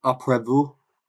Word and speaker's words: Après vous: Après vous.